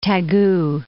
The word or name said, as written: Taegu